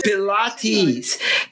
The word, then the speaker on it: Pilates: Pilates.